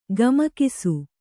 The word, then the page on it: ಗಮಕಿಸು: gamakisu